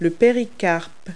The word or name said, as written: pericarp